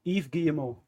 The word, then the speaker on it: Yves Guillemot: Yves Guillemot.